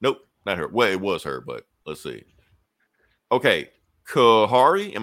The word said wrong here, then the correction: Kahori: Gahori. – Gahori. Gahori. Okay, it's Mohawk. So the Mohawk language, they pronounce their K's like G's mm-hmm. and their R's like D's. So it's Gahori.